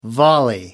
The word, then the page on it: volley: American English